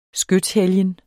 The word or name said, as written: skytshelgen